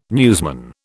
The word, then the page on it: newsman: British English